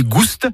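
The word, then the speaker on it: goust: Ni goût ni gouste, avec prononciation du S originel que l'on retrouve dans le provençal goustus, goûteux, et qui a été remplacé depuis par l'accent circonflexe dans notre orthographe moderne.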